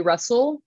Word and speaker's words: Russell: russell um, with two s's and two l's because a lot of people spell my last name wrong um, that's my main page.